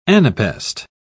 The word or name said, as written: anapest